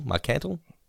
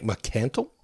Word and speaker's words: Marcantel: How do you reckon I should say this? M-A-R-C-A-N-T-A-L. Marcantel? Marcantel? – I think Marcantel.